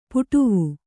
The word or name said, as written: ಪಡಾವು